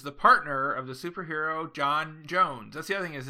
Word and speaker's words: J'onn J'onzz: J'onn J'onzz because there's an apostrophe. – That's how you so pronounce that.